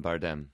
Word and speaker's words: Bardem: Bardem.